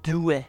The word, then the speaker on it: Dewey: Dewey, on prononce comment Dewey.